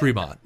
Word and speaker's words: Rimac: So, not to mistaken, if anybody ever watched this on any British TV, they were pronouncing it Rimac. Rimac, but it's Rimac. – Well, the problem is Croatian. We're probably not even pronouncing it right. But from what we've looked up and heard, Rimac is, I think, close.